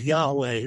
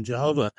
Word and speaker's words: Yahweh: And today scholars believe that the correct pronunciation of the four Hebrew letters is Yahweh rather than Jehovah.